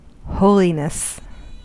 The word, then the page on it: holiness: American English